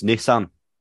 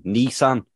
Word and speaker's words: Nissan: The way they pronounce Nissan. – Yeah, it's fucking infuriating to be fair. – Because they were going Nissan. Where's the N-E? It's N-I. Niss.